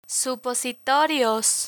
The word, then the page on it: supositorios: Spanish